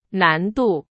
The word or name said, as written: 难度